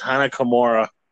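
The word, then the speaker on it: Hana Kimura: Cause, trying to, you know, how you say it again? Ayana Kimura? – I think it's pronounced Hanakamura.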